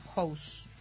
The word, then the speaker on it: Houzz: Who's. Se escribe H-O-U-Z-Z. Who's.